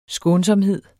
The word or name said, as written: skånsomhed